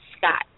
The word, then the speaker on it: Scott: It's Caprice Scott, and I have like rainbow beads on.